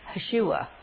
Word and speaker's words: Yeshua: Now, Jesus is the Greek form of the Jewish word Joshua, and it's pronounced in the Hebrew, Yeshua.